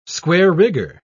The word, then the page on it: square-rigger: British English